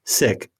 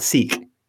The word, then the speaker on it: Sikh: But Tej also reminded me of something a few times that I kept screwing up my whole life, which is S-I-K-H is pronounced sick, folks, not seek. Yep.